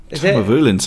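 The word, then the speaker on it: Tamnavulin: I can never pronounce it. Tamavulin? – Tamavulin sounds about right, yeah.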